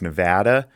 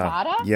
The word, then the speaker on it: Nevada: I, I meant we're uh, we're we're pronunciation Nazis with our state. It's Nevada. – Oh, oh, oh, Nevada. Oh, it's yeah. not Nevada.